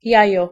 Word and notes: jajo: /ˈjajɔ/

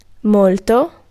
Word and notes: molto: /ˈmɔl.to/

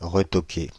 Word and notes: retoquer: [ʁə.tɔ.ke]; /ʁə.tɔ.ke/

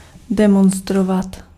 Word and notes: demonstrate: /ˈdɛmənstɹeɪt/

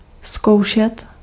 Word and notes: tenter: France; /tɑ̃.te/

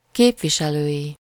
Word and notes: képviselői: /ˈkeːpviʃɛløːʲi/

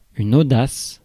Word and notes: audace: France; [o.das]

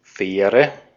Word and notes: ferry: /ˈfɛɹ.i/

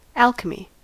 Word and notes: alchemy: /ˈæːlkeˌmiː/; American English

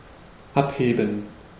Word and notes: abheben: /ˈʔapˌheːbm̩/; /ˈʔapˌheːbən/

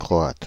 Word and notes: Croate: /kʁɔ.at/

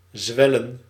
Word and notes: zwellen: /zʋɛ.lə:/

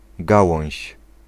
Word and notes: rameau: France; [ʁa.mo]

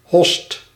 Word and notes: host: /ɦoːst/; /ɦɔst/

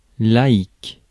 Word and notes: layman: American English; [ˈleɪ.mən]

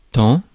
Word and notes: tant: /tɑ̃/

